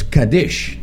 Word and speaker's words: Kaddish: And he says it's Kaddish. So that is the Israeli way of pronouncing it.